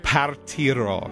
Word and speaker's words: Partirò: Well, that's close enough, but I know you like Italian food, but this is how it's pronounced. Conte Partiro.